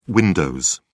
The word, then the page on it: windows: British English